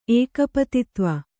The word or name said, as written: ಏಕಪತಿತ್ವ